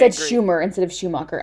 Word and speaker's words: Schumacher: RIP, because Joel Schumer just passed away in 2020, so RIP to him. But great director. – Schumacher. – Yes, yes. Is that, did I mispronounce his last name? I'm sorry. Joel Schumacher.